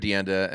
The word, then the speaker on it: DeAnda: At Jared Deanda.